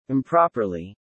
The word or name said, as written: improperly